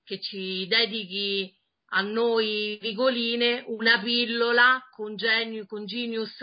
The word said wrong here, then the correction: Genus: Non genius, non c'è la I, vedi, ogni volta che aggiungi una I il disegno diventa sempre più piccolo. – No, questa cosa poi non me la perdonano, dai. – Ogni volta che aggiungi una I diventa sempre più piccolo. – Sentiamolo dalle tue suadenti labbra. – Che cosa? No, è la pronuncia esatta? – Sentiamo. – Allora, in italiano è genus, senza nessuna I.